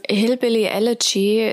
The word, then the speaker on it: Hillbilly Elegy: Ähm, Hillbilly Leach oder wie spricht man das aus? – Ähm, Hillbilly Elegy ist ein autobiografisches Buch von J.D.